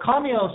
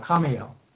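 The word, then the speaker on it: Khamael: So Kamiel is spelled K-H-A-M-E-L. K-H-A-M-A-E-L. I'm sorry, M-A-E-L, Kamiel.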